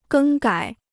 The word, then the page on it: 更改: gēng gǎi